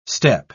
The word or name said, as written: step